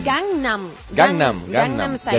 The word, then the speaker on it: Gangnam: Gangman. – No, Gangman. – Gangnam Style.